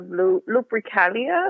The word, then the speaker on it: Lupercalia: From February 13th to the 15th, the Romans celebrated the Feast of Lupercalia, if I'm pronouncing that correctly. – Yes, that's correct, yes.